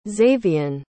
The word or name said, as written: zayvian